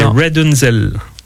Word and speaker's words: RDNZL: Et tu veux savoir comment on se prononce R.D.N.Z.L. – R.D.N.Z.L. – C'est Redunzel. – Redunzel, d'accord. – Qui est une construction. – Contraction des mots redundant et Rapunzel.